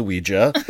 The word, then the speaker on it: Ouija: I was the guy who was like, it's pronounced Ouija.